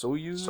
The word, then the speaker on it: Soyuz: Soyuz? – Soyuz.